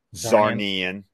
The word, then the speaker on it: Zarnian: But, you know, the idea that, you know, Superman being the last Kryptonian and Lobo being the last Zarnian, which I did confirm, that's how you pronounce it. Zarnian. – Yeah. – Yeah. For me, I was thinking about it sounds like Narnian.